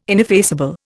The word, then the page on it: ineffaceable: Indian